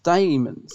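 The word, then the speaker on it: Dæmons: Well, yeah, I was going to say it's funny that because I think it's pronounced Damons. – I think so. – And yeah. and one of the things I've always kicked myself is in the video I did, um, where I went to the location, uh, Aldbourne. I call it the Demons, and every time I watch that video, I'm like, oh, I can't believe I said it wrong, and I say it a couple of times throughout the video.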